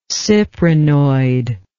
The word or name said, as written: cyprinoid